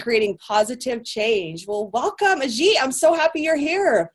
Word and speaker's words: Ajit: And today, I have Ajit Nawaka. I hope I'm saying that right. Do I have it right? – You got it right.